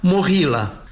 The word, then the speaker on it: Moghilă: Îi spunem Movilă din familia Movileștilor, domni ai țării românești și Moldovei, însă Ucraina l-a cunoscut și îl cunoaște ca Moghilă, pronunțat Mohila.